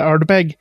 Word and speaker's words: Ardbeg: att betoningen sitter på nummer två där, Ardbeg.